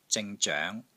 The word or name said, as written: ZING3 ZOENG2